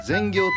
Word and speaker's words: 善行: 良い行いと書いて善行と読みます。